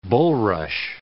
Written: bullrush

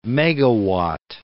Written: megawatt

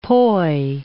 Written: poi

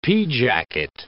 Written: pea jacket

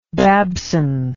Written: Babson

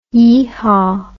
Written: yeehaw

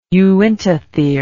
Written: uintathere

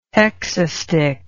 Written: hexastich

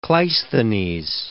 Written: Cleisthenes

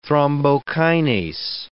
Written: thrombokinase

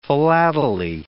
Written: philately

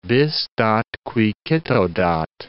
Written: bis dat qui cito dat

